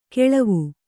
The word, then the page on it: ಕೆಳವು: keḷavu